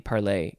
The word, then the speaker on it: parley: I think it might be parlay, even though it's spelled with an EY.